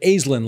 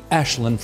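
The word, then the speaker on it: Aisling: Thank you very much, because I think we all pronounced it Aislinn last week, which is the phonetic pronunciation. But given that it's Gaelic, it'll always be Ashlyn from here on out.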